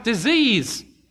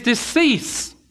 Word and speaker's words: decease: I've heard that pronounced disease. His decease.